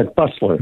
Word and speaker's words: Busler: Michael, I want to make sure I have your I'm pronouncing your name correctly. B-U-S-L-E-R. – Yeah, Buesler.